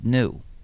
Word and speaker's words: GNU: And not only that, the word GNU is used in lots of humor because according to the dictionary, it's supposed to be pronounced new.